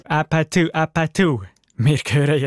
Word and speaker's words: APT: It looks like you need to pronounce ew, ew. No. You should pronounce it like uh, uh. So it's a pa tu.